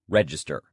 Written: register

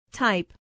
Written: type

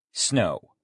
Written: snow

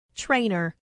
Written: trainer

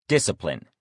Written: discipline

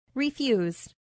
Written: refuse

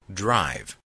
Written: drive

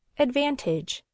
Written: advantage

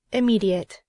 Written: immediate

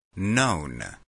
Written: known

